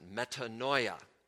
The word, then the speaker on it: metanoia: We pronounce it metanoia.